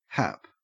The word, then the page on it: hap: Australian English